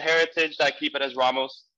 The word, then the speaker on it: Ramos: And Spanish is like super straightforward, where so the vowel sounds for A is just A. And so you would say Ramos.